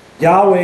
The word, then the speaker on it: Yahweh: Yahweh is the Hebrew pronunciation.